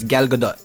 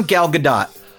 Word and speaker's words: Gal Gadot: But it's Gal Gadot, right? – Gal Gadot.